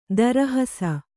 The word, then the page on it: ತಿರುಹಿಸು: tirihisu